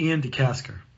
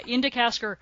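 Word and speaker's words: De Caestecker: And again, I gotta say, Ian, how do you say that? Ian DeCastaker? Is that what his name is? – I believe it's Ian DeCastaker. – Yeah, Ian DeCastaker.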